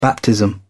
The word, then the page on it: baptism: British English